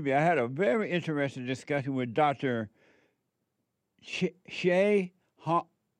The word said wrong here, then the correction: Ahn: Hong. Whatever. A-H-N. How do you pronounce that? – On. – On. On.